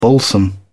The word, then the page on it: balsam: British English